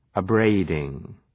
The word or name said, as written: abrading